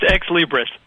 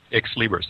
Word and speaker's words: Xlibris: And before joining Afilias, he was a vice president and chief marketing officer at, boy, I can't even pronounce it, X-L-I-B-R-I-S. How do you pronounce that? – It's Ex-Libris. – Ex-Libris. There you go.